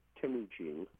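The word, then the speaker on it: Temüjin: Yes, I say Temujin, but there's no agreement on where the stresses should rest, so you can pronounce it really how you like.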